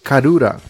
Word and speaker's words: Karura: Pô, dei uma pesquisada aqui e achei nego falando que o Rei Carla, em japonês, se pronuncia Karura.